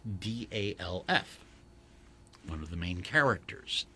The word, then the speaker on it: Gandalf: And he said it rhymes with half, as in half a sixpence. – Oh, Gandalf.